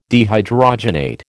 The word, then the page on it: dehydrogenate: American English